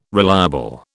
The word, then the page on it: reliable: British English